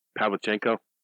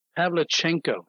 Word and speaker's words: Pavlichenko: I can't pronounce her name. Pavlichenko. – Pavlichenko. – Actually, I did pretty good with that. – You did.